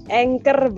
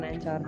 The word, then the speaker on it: anchor: Anchor bacanya tuh, bukan Ancor.